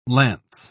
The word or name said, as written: lance